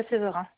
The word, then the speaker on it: Severin: Mon nom, c'est Jessica Severin. – Le nom s'écrit comment? – Le nom de famille, S-E-V-E-R-I-N.